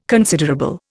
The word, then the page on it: considerable: Indian